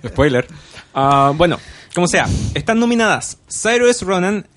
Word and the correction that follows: Saoirse: eh, eh, por papel K. Saoirse Ronan. – Saoirse. – Es muy difícil pronunciar su nombre porque está es irlandés.